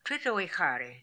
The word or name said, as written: Tritoehary